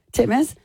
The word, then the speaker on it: Titmus: Titmus.